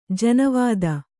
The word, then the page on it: ಜನವೇದಿ: janavēdi